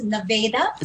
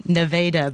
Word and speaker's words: Nevada: Just to correct the pronunciation of our center, it's Nevada. – Sorry, beg your pardon. Nevada, beg your pardon.